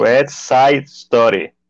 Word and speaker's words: West Side Story: West White Story, lo dije bien. – West Side Story.